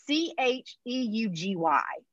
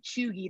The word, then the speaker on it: cheugy: So basically, so it's spelled C H E U G Y. So of mm-hmm. course I am not pronouncing it chuggy the first time I read it, but luckily as I continue to read the article, it explains how to say it and it, it is pronounced cheugy. So basically it's like another term for like basic but so I'm going to read you the Urban Dictionary, okay.